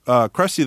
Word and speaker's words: Krusty: Anyway, so Krusty the Clown, Krusty rhymes with Rusty, and I actually created him as a little on-screen character on the Tracy Ullman show for those little shorts there.